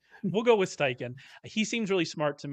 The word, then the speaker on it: Steichen: I think that Steichen is, am I saying that right? I actually have never figured out how to say his name. I say Steichen.